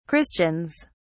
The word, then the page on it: christians: British English